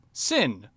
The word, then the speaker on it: Xin: Sin, which is spelled X-I-N. So it's actually a Chinese name and pronunciation.